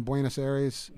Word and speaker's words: Buenos Aires: You know, we picked out the cover, which is a shot of us in Buenos Aires.